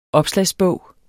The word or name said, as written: opslagsbog